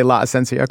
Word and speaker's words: La Esencia: But did I say La Esencia correctly? – You said it correctly.